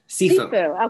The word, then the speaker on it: Sifu: Sifu.